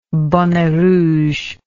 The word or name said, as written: bonnet rouge